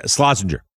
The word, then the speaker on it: Slazenger: And there is a lot of product placement in the movie, including Corgi, Aston Martin, Dom Perignon, Slazenger, I'm saying that right? The golf company? Slazenger? – Yeah, Slazenger.